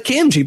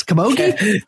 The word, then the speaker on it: Camogie: I Googled the like how to pronounce it, and I said it was a Camogie, but no, it's Camogie.